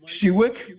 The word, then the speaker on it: Sewick: Mike Sewick. Last name is spelled S-E-W-I-C-K.